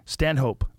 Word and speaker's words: Stanhope: Did you say his name's Doug Stand-up? – Stanhope. – Oh, Stanhope. – Yeah.